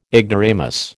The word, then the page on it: ignoramus: American English